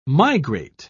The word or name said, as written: migrate